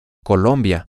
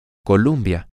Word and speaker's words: Colombia: Utilizarás la pronunciación correcta si lo dices con el sonido O, Colombia, en lugar de la UM, Columbia.